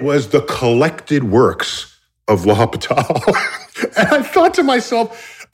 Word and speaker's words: L'Hôpital: But there's a rule in calculus called L'Hôpital's rule, L'Hôpital, and it's almost spelled like hospital, but it's L'Hôpital.